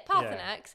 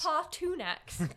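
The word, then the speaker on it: Paarthurnax: Yeah, Parthanax is how it's said, how it's pronounced, but my brain goes Parthunax. – Partunax.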